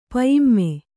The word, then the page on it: ಪಯಿಮ್ಮೆ: Kannada